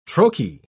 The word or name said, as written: trochee